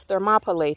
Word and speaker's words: Thermopylae: And in addition, go Google the Battle of Thermopylae. Thermopylae is spelled thermo, like a thermos, thermo, meaning heat, Thermopylae, P-Y-L-A-E, Thermopylae, the hot gates, okay?